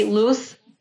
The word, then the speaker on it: Luz: I'll say Luz, L-U-Z, and then I pause.